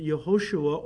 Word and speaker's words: Yehoshua: In fact, the Greek name for Jesus comes from Jehovah Shua, or as the Jews pronounced it, Yehoshua, or they would shorten it to Yeshua.